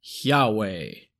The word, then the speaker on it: Yahweh: This name is pronounced Yahweh.